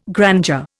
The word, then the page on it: grandeur: Indian